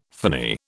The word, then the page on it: phony: British English